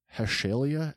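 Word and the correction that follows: Hetalia: Axis Powers? – Oh, boy. – Am I reading that correctly? – Hatalia.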